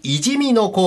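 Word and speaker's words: 五十公野: 毎年 5 月から柴田市いじみの公園、えー、関数字の 50 に公の野原と書いていじみのと読むんですが、いじみの公園入り口近くの敷地で、地域のみんなで雑煮合戦で使うあるものを育てております。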